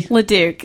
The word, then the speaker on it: LeDuc: LeDuc, yeah.